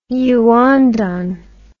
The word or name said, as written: yuandan